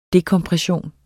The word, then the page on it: dekompression: ˈdekʌmpʁεˌɕoˀn; dekʌmpʁεˈɕoˀn